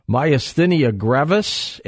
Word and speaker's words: myasthenia gravis: He says, my dad is 82, Doc, has severe, you know, let's see if I can pronounce this right, myasthenia gravis. Any ideas of what he can do to minimize it? Did I pronounce that right? – You did perfect. You get an A+.